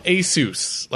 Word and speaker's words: Asus: Asus, like Dr. Seuss.